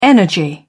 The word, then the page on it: energy: British English